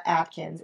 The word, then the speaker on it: Adkins: It's Ashley Lorena Adkins. Adkins is spelled with a D.